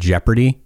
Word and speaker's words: jeopardy: But the word jeopardy just kills me every time. I have to, I don't know why. It's a strange word that's spelled, and it's like, why is there an O there? And I hope we, and I don't know if it's P-R-D.